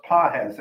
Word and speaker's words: Pages: His name is spelled Pages, but it's pronounced Pajes, Andy Pajes.